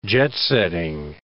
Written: jet-setting

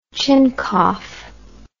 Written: chincough

